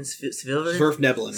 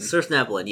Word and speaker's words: Svirfneblin: Svirfneblin. – Svirfneblin, yeah.